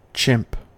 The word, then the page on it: chimp: American English